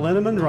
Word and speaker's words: Linneman: Val Linneman, Rhymes with Cinnamon,